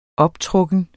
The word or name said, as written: optrukken